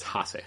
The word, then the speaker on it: Hase: Japanese wrestler Hiroshi Hase, I think it's not Hase. I always used to say Hase, but that would not be how Japanese would pronounce it. So I'm going to say it's Hase, Hiroshi Hase, taking on Jean-Pierre Lafitte.